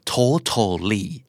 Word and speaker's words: totally: totally T O T A L L Y totally อเมริกันออกเสียงว่า totally